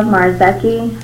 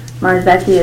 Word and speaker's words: Marzeki: Yes, my name is Layla Marziki. Marziki is spelled M-A-R-Z-E-K-I.